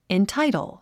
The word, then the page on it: entitle: intáitl; インタイトル